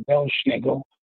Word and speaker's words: Belsnickel: It's a mispronunciation of two German words. It's Pels Nicholas. It actually meant a furry Saint Nicholas. And Pels Nicholas got sort of anglicized over the years to Pels Nicholas, to Belsnickel.